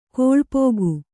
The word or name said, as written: ಕೊಳ್ಪೋಗು